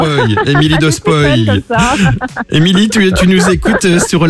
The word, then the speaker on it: Spoy: Ah, Émilie qui habite à Spoy dans l'Aube et non pas Spoy parce que ça s'écrit S-P-O-Y.